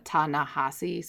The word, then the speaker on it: Ta-Nehisi: I pronounced um, the editor's name wrong. It's uh, Ta-Nehisi, so I just wanted to correct that for um, Vanity Fair.